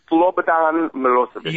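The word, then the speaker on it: Slobodan Milošević: And I had him losing in an upset to Serbia's Slobodan Milosevic, is that how you pronounce it? – Slobodan Milosevic.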